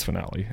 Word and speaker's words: Finale: I think it's Finale is how I would pronounce it.